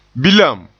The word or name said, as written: bilam